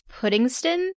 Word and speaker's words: Puddingstone: It's Puddingston, spelled Puddingstone, but in the New England way of things, it's not pronounced exactly as it's spelled.